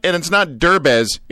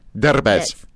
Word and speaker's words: Derbez: And it's not Derbez. It's Derbez. – Derbez. – Derbez. – Very nice. – I'm sorry. I put the wrong emphasis on the right syllable.